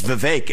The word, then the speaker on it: Vivek: If I say my name's Vivek, it rhymes with cake.